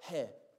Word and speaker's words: he: We actually, you'll say it like hey, H-E-Y, but it's the letter he, fifth letter.